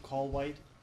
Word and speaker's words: Kallweit: It's David Wayne Callwhite, K-A-L-L-W-E-I-T.